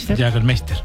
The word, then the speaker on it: Jägermeister: Jägermeister.